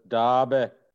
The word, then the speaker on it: Derby: For our non-UK listeners, Derby is where they will pronounce it Derby. – It's quite a good, you did that well.